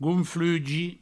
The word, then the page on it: gonflugi: gounflugi